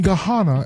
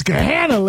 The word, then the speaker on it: Gahanna: That's how my map GPS says in Gahanna. Gahanna, they don't say Gahanna. I'm like, Gahanna, you know they not from Ohio when they pronounce our cities and our streets wrong. Gahanna, it's going to be 74 degrees. It's Gahanna, lady.